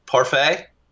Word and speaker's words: parfait: Give me one other word that ends with a silent T. Billy can help you out. – Parfait? – Yes.